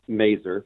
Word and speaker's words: Mazur: Yeah, my name is Jeremy Mazur. That's spelled M-A-Z-U-R.